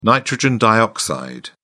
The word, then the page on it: nitrogen dioxide: British English